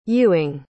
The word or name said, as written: Ewing